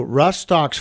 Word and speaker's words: Rhus Tox: So Rhus Tox, folks, is spelled R H U S. Tox T O X, which stands for toxicodendron.